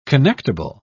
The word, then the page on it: connectible: British English